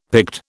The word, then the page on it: Pict: British English